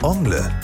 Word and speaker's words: Ongle: Das französische Wort Ongle bedeutet Zehennagel. Wird das Wort mit deutscher Betonung ausgesprochen, hört es sich so ähnlich an wie Onkel.